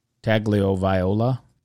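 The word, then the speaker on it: Tagovailoa: Tagliavola. – There you go. I think you actually said it right.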